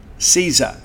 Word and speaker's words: SZA: CZA. It's spelled S-Z-A.